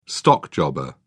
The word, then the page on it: stockjobber: British English